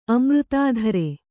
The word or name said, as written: ಅಮೃತಾಧರೆ